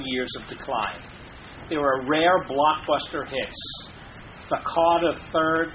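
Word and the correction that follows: The Carter III: or how do you pronounce that? The Carter Roman Three. – The Carter Three.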